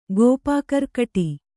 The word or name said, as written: ಗೋಪಾಕರ್ಕಟಿ